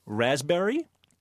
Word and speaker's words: raspberry: K-N-E-E-L, Neil. – Raspberry, answer. – Silent letters. – Yes, silent letters. – Oh, raspberry is... – The silent S. – P. – Well, P. Sorry. – Silent P, right.